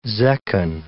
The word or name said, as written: zechin